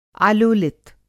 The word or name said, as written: आलोलित